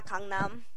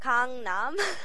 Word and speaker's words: Gangnam: Yeah, Gangnam. – What is it? – Gangnam.